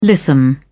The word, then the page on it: lissome: British English